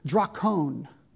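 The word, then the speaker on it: drakon (δράκων): In our usage in English, dragon, which comes, by the way, from the Greek word here, let me give you a literal pronunciation of the word, drakon.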